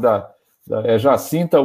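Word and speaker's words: Jacinda: Comanda, ela, é, é, chama, é, é, é, Jacinta tem, uma, tem umas consoantes do meio ali do nome, mas você pronuncia Jacinta, esqueci o sobrenome.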